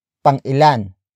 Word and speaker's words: pang-ilan: Word for word, it's pang-ilan ka sa magkakapatid.